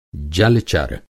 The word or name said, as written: जल-चर